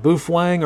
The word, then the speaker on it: Baofeng: Is Baofeng pronounced Boofwang or Baofeng? It's definitely Boofwang. I've been wrong. I've been lying all these years. It is absolutely Boofwang. Boofwang.